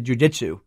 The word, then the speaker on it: jujitsu: They did jujitsu.